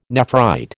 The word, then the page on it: nephrite: American English